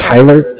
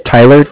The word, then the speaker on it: Tyler: My name is Tyler. Tyler, T-Y-L-E-R. – Got it. Yes. – And I'm calling with Cardiff. It's spelled C-A-R-D-I-F-F, as in Frank.